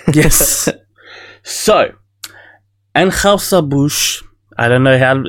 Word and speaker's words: Anheuser-Busch: Anheuser-Busch.